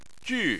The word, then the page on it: ju4: chinese